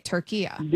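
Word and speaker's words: Türkiye: And so now the instruction is that also in English, say it like how it's essentially supposed to be pronounced. Okay, Turkiya. – There you go. That's perfect. That's how it's pronounced. It's always been pronounced that in Arabic language. – Okay. – It's a short A at the end, not the long A.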